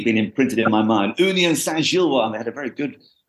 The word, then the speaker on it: Union Saint-Gilloise: Union Saint-Gilloise, to give it its absolutely correct pronunciation,